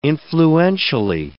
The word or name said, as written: influentially